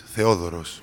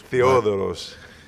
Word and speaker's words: Theodoros: Theodora? Well, how do we say this? – In Greek, it's Theodoros. – Theodoros. – All over the world is Theo.